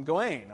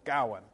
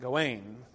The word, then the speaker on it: Gawain: Um, but here, this is alliterative verse, and the emphasis is on the first syllable, Gawain. Um, he's clearly not Gawain, because the emphasis, the alliteration, is usually on the G, so that's what's clearly supposed to be emphasized.